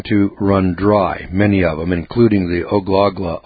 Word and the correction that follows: Ogallala: Aquifer, if I'm pronouncing that right, Ogallala, O-G-A-L-L-A-L-A. It's an Indian word, a little tricky for us white boys to pronounce.